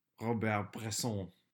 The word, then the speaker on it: Bresson: Robert Bresson.